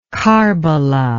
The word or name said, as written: karbala